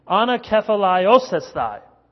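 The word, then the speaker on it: anakephaliosthai (ἀνακεφαλαιώσασθαι): That's just a simple word in Greek or in English, but in Greek it is anakephaliosthai. Eight syllables. Anakephaliosthai.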